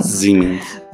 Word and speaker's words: Siemens: Siemens.